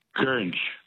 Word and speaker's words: goringe: The word that rhymes with orange is gurnj. – Is what? Gurnj? – Gurnj, yeah. – What's the spelling of gurnj? – G-O-R-I-N-G-E.